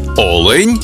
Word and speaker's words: олень: в однині і в множині наголос падає на перший склад – олень та олені.